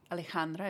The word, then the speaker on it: Alejandra: And I am Ali, Carroll, Alejandra, if you can pronounce it.